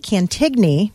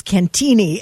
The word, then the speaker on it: Cantigny: I grew up calling it Cantigny because that's how it's spelled. – So did everybody. – And my grandfather, who had far more knowledge of this, kept saying it's Cantini.